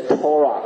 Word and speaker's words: Torah: 那圣经里面的 Torah 不是一个冷冰冰的律法，所以弟兄姐妹，我们以后呢碰到这个在圣经上看到这个律法的时候呢，你把它读成 Torah 好了，好不好？